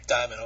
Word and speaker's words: Dimon: Garrett Davis is taking on Jeremy, I hope I'm pronouncing the last name correct, Dimon or? – Diamond, okay. – Just like the stone, only spelled different.